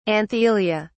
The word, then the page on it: anthelia: British English